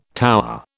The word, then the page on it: tower: British English